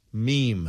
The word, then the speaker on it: meme: Meme. – Meme. Did you hear that, Keith? You want to hear it again one more time? – Meme. – There's not a second syllable to it.